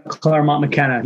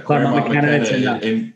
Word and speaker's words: Claremont McKenna: First of all, you graduated from, and correct me if I'm pronouncing this wrong, but Claremont McKenna College. – Claremont McKenna. – Claremont McKenna. In California.